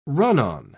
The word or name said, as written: run-on